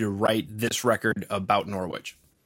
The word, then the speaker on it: Norwich: Did I say that right? Norwich? – Yes. Yep.